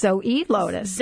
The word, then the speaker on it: Zoelotus: Well, and what I wanted to mention to everyone, and I totally mispronounced, you know, the website, it's Zoelotus. – Zoelotus, but spelled like Zoe, so Z-O-E.